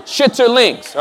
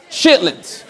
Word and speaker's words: chitterlings: Some of you, and I'm pronouncing it correctly, okay? From California, chitterlings, all right? Now let me move down south. Chitlins, okay? They're called chitlins in the South.